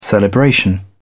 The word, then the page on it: celebration: British English